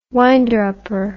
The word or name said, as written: winder-upper